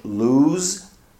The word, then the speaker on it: lose: The verb lose, spelled LOSE, is pronounced with a Z sound.